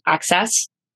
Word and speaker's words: AXS: It's spelled Access, but it's pronounced Access.